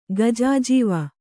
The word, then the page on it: ಗಜಾಜೀವ: gajājīva